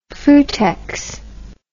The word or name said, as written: frutex